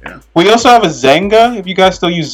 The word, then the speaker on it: Xanga: Yeah, Zanga.